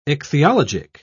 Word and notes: ichthyologic: British English

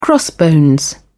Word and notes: crossbones: British English

